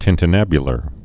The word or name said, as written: tintinnabular